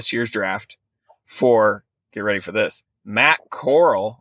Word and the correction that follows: Corral: or Corral. Can't remember how to pronounce his name. – It's Corral.